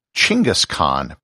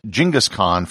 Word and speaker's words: Genghis Khan: And it's also sometimes pronounced Chinggis Khan, but I'm going to continue with the anglicized Genghis Khan for the rest of this episode.